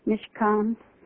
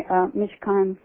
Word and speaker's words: Mishkan: The word, the way they spell it, it's M-I-S-H-K-A-N, Mishkan.